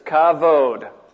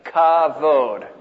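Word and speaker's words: kavod (כבוד): That's a K, that's an A. So that's ka, and this here is pronounced like a V, so that's kav, that's an O, and that's a D, so it's kavod.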